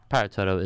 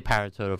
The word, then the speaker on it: Paratodo: Like, well, that's how you would enunciate it in Spanish. Mm-hmm. That's a pronunciation. Yeah. But Paratodo is acceptable. I say Paratodo for the most part, just because I'm mainly talking to people who don't speak Spanish.